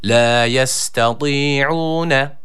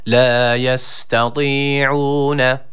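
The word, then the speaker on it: La yestati'une (لا يستطيعون): Öncelikle tı harfine dikkat ediyoruz. ı-ı arası bir sesle bir elif miktarı uzatıyoruz. Kalın bir harftir. La yestati'une.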